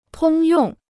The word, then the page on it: 通用: tōng yòng